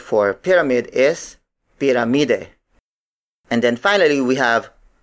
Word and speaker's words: piramide: Lata, the Tagalog word for can, is spelled as L-A-T-A. Again, the Tagalog word for cylinder is cilindro. Up next, we have piramide. Piramide. Piramide. Piramide is spelled as P-I-R-A-M-I-D-E.